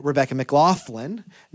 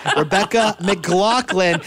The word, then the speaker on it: Rebecca McLaughlin: It's co-hosted by yours truly, me, and Rebecca McLaughlin. I said it wrong again, Rebecca. Rebecca McLaughlin.